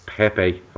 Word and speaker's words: Pepe: Now, the one we're going to touch on, because it's been the strongest link all week, is Nicolas Pepe.